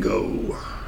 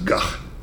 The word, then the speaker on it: Van Gogh: All right, and this date in 1890, we lost Mr. Vincent Van Gogh. Strange, because the actual pronunciation of his name is Gach. But everybody calls him Go.